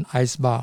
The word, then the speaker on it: ISBAR: 叫做啊 S bar 哦也。也有人念成 ice bar 哦，那无论如何，等一下我们会稍微深入哦。